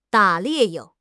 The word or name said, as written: 打猎